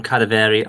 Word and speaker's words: Kadaveri: Hello, I go by the name of Kadaveri.